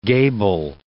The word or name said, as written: gable